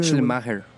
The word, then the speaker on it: Schleiermacher: Hay un teólogo acá, me recorrigió cómo se pronuncia. Schleiermacher, algo así yo le digo. Schleiermacher. – Sí. – Padre de la teología liberal. – Sí, ¿cómo puede decir? – Schleiermacher.